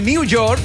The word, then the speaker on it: New York: En Nueva York. Pronunciado correctamente, New York.